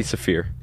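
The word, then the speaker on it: Siffre: Kenneth Chase by Labi Sif. I don't know how to pronounce his name. – Labi Safir.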